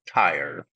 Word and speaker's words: Tyre: I will pronounce it Tyre, I hope, throughout the entire program. Sometimes I lean towards pronouncing it here, but that's because the Hebrew spelling is actually Taurus, Turos.